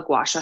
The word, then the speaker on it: gua sha: So most people have the instinct to gua sha way too hard.